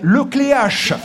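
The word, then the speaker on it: Le Cléac'h: Alors, pardon aux Bretons pour la prononciation, on hésite. Le Cléac ou le Cléache? – Armel Le Cléache.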